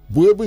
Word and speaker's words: Buebu: Please, you can follow me on Twitter at Godwin _ Buebu. Buebu is spelled as BUEBU.